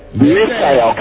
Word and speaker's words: Misael: Rhymes with Israel. – Okay. Misael. Kind of like Israel, but Misael.